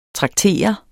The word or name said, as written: traktere